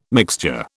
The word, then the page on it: mixture: British English